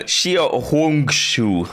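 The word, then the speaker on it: Xia Hongshu (小红书): Más néven Xia Hongshu.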